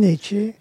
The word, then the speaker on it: Nietzsche: Was it Nietzsche or how do you pronounce his name? – Nietzsche.